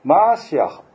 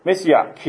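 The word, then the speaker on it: マ ー シア ハ (מָשִׁיחַ): で、この油注がれたものというのをヘブル語ではマーシアハって言います。マーシアハ。つまりメシア。日本語的に発音するとメシアです。これはギリシャ語のクリストス、つまりキリストと同じ意味です。メシア、キリストはやがて神様がこの地上に送ると約束された救い主を指す言葉になったんです。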